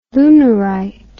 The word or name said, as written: lunarite